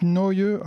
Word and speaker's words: Neu!: Le thème d'aujourd'hui c'était Krautrock et on a pu écouter à l'instant l'excellent groupe Noyeux. Alors ça s'écrit N-E-U avec un point d'exclamation, ça date de 71 et voilà, c'est un groupe qui est...